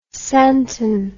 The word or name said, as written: santon